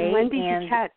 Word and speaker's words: Wende Dikec: I'm Wende Dikec.